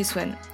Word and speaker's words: Swan: le toi et bienvenue dans mon podcast Du côté de chez Swan. Et oui, c'est comme ça que ça se prononce.